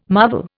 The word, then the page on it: marvel: Indian